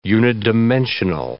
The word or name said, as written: unidimensional